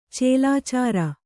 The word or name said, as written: ಚೇಲಾಚಾರ